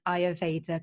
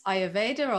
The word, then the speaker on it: Ayurveda: Am I pronouncing Ayurveda correctly? – You are, yes. Ayurveda, or some people say Ayurveda, but yes, Ayurveda and Ayurvedic, yes.